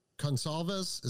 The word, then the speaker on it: Goncalves: One of the uh, and I think it was uh, Goncalves. Is that how you pronounce the one girl's name? Yep. Uh,